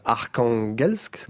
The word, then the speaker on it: Arkhangelsk: Une des villes les plus au nord de la Russie, Arkhangelsk, comme on prononce comme ça, Saint-Pétersbourg, une ville au nord de la Mongolie, et Astrakhan.